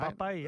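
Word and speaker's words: Mbappé: Mbappe, is that how you say his name? Mbappe, yep.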